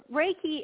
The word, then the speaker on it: Reiki: So Reiki is simply put, is it's pronounced Reiki, and it's spelled R-E-I-K-I.